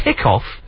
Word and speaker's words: hiccough: You can pronounce cough as cup. Well, yeah, you can do, yeah. When it's... Because it's hiccough, is how it's spelt.